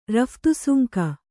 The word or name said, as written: ರಫ್ತುಸುಂಕ